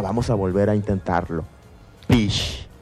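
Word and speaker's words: pix: que en lengua yocotán se pronuncia pish. – Sí, no te preocupes, aquí lo vamos a repetir nuevamente. Pish, así como lo escuchas, vamos a repetirlo nuevamente. Pish. Excelente. ¿Cómo se escribe? Pues vamos a anotar. Primero, letra P. – Letra P. – La letra I.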